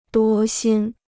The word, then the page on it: 多心: duō xīn